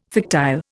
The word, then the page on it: fictile: Indian